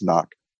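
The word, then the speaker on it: CNOC: I guess some people pronounce it C-Nock, but I'm pretty sure it's Nock.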